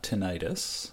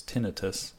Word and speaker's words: tinnitus: Side effects, we mentioned GI bleeding, allergic reactions, interstitial nephritis, tinnitus, and by the way, that's not tinnitus, which you'll be tempted to pronounce that way, it's tinnitus, and hepatitis.